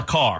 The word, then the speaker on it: Makar: Pronounce Caleb McCarr. – No, it's just Cale. – Cale. – Cale, C-A-L-E. And McCarr, M-A-K-A-R. – McCarr.